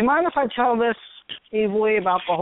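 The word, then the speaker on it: Eevee: The if I remember, she was telling me this correctly, that the Japanese pronunciation is Evie.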